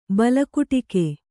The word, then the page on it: ಬಲಕುಟಿಕೆ: bala kuṭike